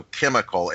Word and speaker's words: chymical: Christian, for those of you who don't know, going way back in Masonic and Rosicrucian Illuminati lore, they have what's called the chemical. And it's spelled C-H-Y-M-I-C-A-L. So chemical. It has more to do with sorcery than anything else.